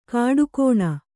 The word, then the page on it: ಖೋಡಿಗಾಣ್: khōḍigāṇ